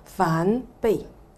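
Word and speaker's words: 梵唄: 咁、啊、但係我哋跟住嚇啲老法師佢哋一路咁樣傳落嚟嗰個讀音發音咧係叫梵悲，啊梵悲嚇。咁、啊、所以呢兩種都可以嘅。咁、另外咧係廣東話嘅發音係梵貝係嘛？梵貝嚇。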